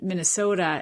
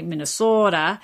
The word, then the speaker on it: Minnesota: And that's why, of course, we say Minnesota, because that's actually the monophthongal residue, to use a big word, of Scandinavian pronunciation, where the vowels were shorter and they don't have that little extra piece on the end like Americans like to say, like, oh, we say oh. But if you're from Finland or Sweden, you'd say oh, oh. So Minnesota. It becomes a shorter pronunciation.